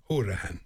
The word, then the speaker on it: Horahan: And then I couldn't remember Horahan or Howrahan. – Right. – And then And then Horahan.